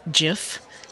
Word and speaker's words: Jif: So the way that I pronounce it is Jif, as with a J and a soft.